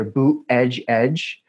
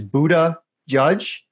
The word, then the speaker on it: Buttigieg: there's there seems to be two popular ways of pronouncing his name, either Boot Edge Edge. I prefer the second one, which is Buddha Judge, because I think that wisdom and discernment are the two qualities that are most lacking in the executive branch today.